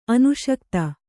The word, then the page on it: ಅನುಷಕ್ತ: anuṣakta